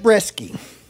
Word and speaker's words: Breske: Chad Breske.